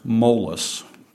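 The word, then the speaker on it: molis (μόλις): Now, in both of the scriptures that we looked at, the word scarcely came from a Greek word pronounced molis, just a simple word, M-O-L-I-S, molis.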